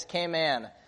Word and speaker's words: Cayman: And Wayne said it right. It is Cayman.